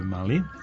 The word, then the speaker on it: Mali: Znači, dobro sam akcentom Mali, nema veze sa Beogradom nikakve, država se zove Mali.